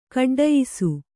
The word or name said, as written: ಕಡ್ಡಯಿಸು